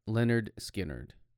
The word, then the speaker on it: Lynyrd Skynyrd: pronounced Lynyrd, Skynyrd.